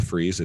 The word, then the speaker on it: Fries: On Twitter, it's just my name, Micah Fries. It's M-I-C-A-H. Fries is spelled like fries, like French fries, F-R-I-E-S.